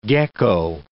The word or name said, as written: gecko